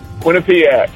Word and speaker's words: Quinnipiac: Did I say it right, Quinnipiac? – Quinnipiac. – All right, what's up, dude? – Not a whole lot.